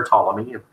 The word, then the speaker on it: Ptolemy: it'll say the king of the south was Ptolemy, or Ptolemy, if the P is silent.